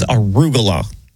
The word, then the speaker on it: arugula: But the correct pronunciation is arugula.